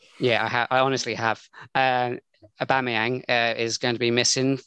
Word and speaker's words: Aubameyang: Apparently, I'm going to try and say his name, Aubameyang. – Yeah, that's actually right.